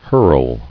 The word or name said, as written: Hurl